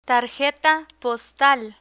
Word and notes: tarjeta postal: Spanish

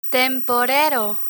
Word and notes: temporero: spanish

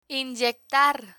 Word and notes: inyectar: Spanish

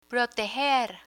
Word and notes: proteger: Spanish

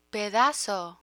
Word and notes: pedazo: Spanish

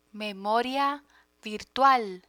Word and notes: memoria virtual: Spanish

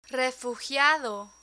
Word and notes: refugiado: Spanish

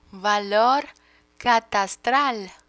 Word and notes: valor catastral: Spanish

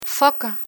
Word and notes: foca: Spanish

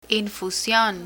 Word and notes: infusion: Spanish

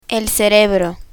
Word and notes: cerebro: spanish

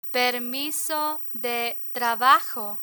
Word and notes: permiso de trabajo: Spanish

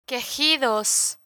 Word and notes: quejidos: spanish

